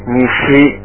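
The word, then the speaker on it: nishi (西): The next is the west is pronounced nishi, nishi.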